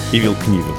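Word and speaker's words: Knievel: И есть каскадер Эдди Браун, и он хотел воссоздать прыжок другого каскадера, Ивила Книвила, с буквой «К» читается. – Ивил Книвил. – Книвил, да, потому что... И в некоторых случаях такое читается Нивил без кей, но здесь с буквы К.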